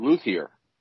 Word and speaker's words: luthier: It is spelled luthier, and that's how most people who make guitars pronounce their trade. But it is really pronounced luthier. So it's obviously kind of a French word, but what are its origins?